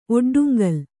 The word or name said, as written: ಒಡ್ಡುಂಗಲ್